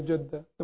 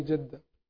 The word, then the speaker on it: Jeddah: The correct pronunciation will be Jeddah, the more correct, but the more popular will be Jeddah.